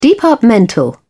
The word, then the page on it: departmental: British English